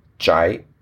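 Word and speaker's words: Jai (翟): Although confusingly, this same character, when used as a surname, should take its alternative pronunciation of Jai.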